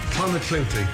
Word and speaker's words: Clonakilty: Irish tea bags or Clonakilty sausages from Cork. – Clonakilty, thank you.